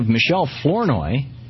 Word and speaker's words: Michelle Flournoy: And we have Michelle Flournoy.